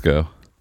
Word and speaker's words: VSCO: It's Visco. It's from the French word.